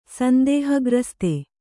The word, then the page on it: ಸಂದೇಹಗ್ರಸ್ತ: sandēha grasta